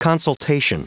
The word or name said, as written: consultation